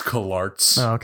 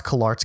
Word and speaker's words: CalArts: It's pronounced CalArts, actually. – It's pronounced CalArts.